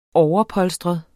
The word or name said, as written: overpolstret